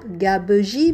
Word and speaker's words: gabegie: G-A-B-E-G-I-E. E. Trois syllabes, gabegie, mais on dit gabegie.